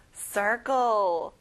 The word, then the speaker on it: circle: 원형, circle. circle이 아니라 R 발음을 강하게 들려주세요.